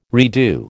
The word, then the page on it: redo: American English